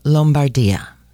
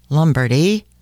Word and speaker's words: Lombardia: Milan is actually in the region of Lombardia. That's how they pronounce it in Italy. It's beautiful. Lombardia. Americans say Lombardy or Lombardy. Drives me crazy.